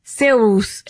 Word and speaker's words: Cervus: Cervus. And V makes a woo sound.